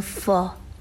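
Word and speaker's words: phở: مهمترین و اصلی ترین و معروف ترین غذای ویتنام غذایی هست به اسم فا. حالا تلفظش هم یه مقدار سخته، فو نوشته میشه ولی فا خونده میشه، فا هم نه یعنی آ هم نه، یه چیزی بین ا و آ، فا.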